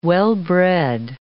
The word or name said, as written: well-bred